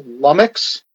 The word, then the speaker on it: LVMMVX: So he does a noise project that I believe is pronounced Lummix, but it's LVMMVX.